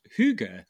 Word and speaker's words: hygge: Hygge? Hygge? Is that how you say it? Yeah.